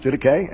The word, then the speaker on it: Didache: It's pronounced Didache.